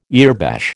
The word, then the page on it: earbash: American English